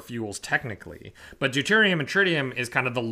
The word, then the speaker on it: tritium: Yes, deuterium and tritium.